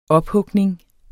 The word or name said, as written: ophugning